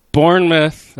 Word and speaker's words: Bournemouth: Bournemouth, that's how you pronounce it, is... – Well said, mate. That's very English of you.